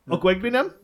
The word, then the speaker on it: Okwuegbunam: I can't pronounce this guy's last name, but it's Albert Okwuegbunam. I don't know how it says. – Okwuegbunam? – Yeah, there we go.